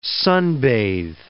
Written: sunbathe

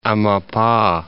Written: Amapa

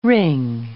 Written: wring

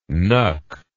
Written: knuck

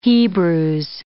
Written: Hebrews

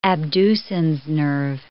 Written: abducens nerve

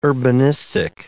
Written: urbanistic

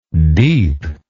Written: deepness

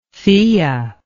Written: thea

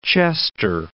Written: Chester

